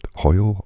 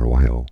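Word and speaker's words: João: It's spelled J-O-A-O, so it's Hoyo or Wayo,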